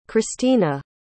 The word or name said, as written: christina